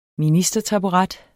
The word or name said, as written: ministertaburet